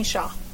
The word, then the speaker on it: Shah: V-I-A-N-C-C-I? – C-H-I. – C-H-I. – Lakshmi Shah. – How do you spell that? – L-A-K-S-H-M-I. – Okay. – S-H-A-H. – H-A-H.